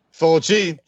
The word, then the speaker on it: Fulci: And did I say it right? Fulci. Fulci. Fulci. Yeah.